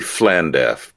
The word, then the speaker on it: Llandaff: I think it's supposed to be Llandaff, but LL is pronounced FL, but we'll call it Llandaff for our American audience.